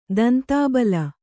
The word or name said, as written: ತಂತಿಬಲೆ